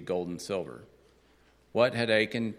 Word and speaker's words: Achan: Achan.